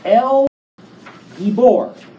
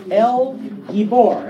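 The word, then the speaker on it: El Gibor (אל גיבור): The Hebrew name, El Gibor. El Gibor. Everybody say that with me. El Gibor. Okay. Emphasis on the bor.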